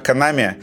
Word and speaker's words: Konami: на все Konami или Канами. – Канами. Канами. Канами. Хорошо. Канами. Э, Блять, как ужасно звучит. Давайте все-таки Канами, хорошо? Канами.